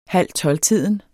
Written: halvtolvtiden

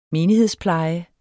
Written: menighedspleje

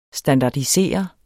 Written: STANDARDISEREDES

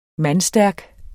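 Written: MANDSTÆRKT

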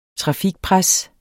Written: trafikpres

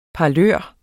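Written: parlør

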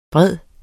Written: bred

